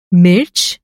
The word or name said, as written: मिर्च